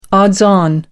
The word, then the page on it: odds-on: American English